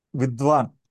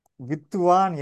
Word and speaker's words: வித்துவான்: அதாவது உதாரணமாக வித்துவம், வித்துவம் இப்படி ஒரு வார்த்தை இருக்குன்னு வைத்து வித்வான், வித்வான் இதுதான் சம்ஸ்கிருத உச்சரிப்பு வித்வான். இதை தமிழில் எழுதும்போது வித்துவான் என்று எழுதுவார்கள், இடையில் தூ சேர்த்து எழுதுவார்கள் வித்துவான்.